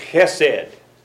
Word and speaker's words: chesed: That first letter, the chet, is very hard to pronounce. But it's pronounced chesed.